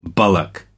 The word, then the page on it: bullock: American English